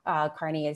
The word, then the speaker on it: Carney: So if you search on my name, it's Robin Pulis Carney, and Pulis is spelled P-U-L-I-S. Carney is C-A-R-N-E-Y.